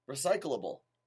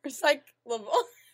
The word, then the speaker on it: recyclable: Recyclable. – No, you're saying it wrong. You're adding something. Recyclable.